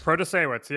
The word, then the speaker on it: Protasiewicz: but Janet Protasiewicz. – Protasiewicz, yeah.